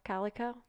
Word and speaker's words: Calico: I assumed it was Calico, like Calico Cat. – That's what I was going to ask you. Is it spelled like that? – I think so. C-A-L-I-C-O, Calico.